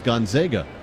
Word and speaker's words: Gonzaga: 3-2, or as we know, Joe, the correct pronunciation in Spokane is Gonzaga.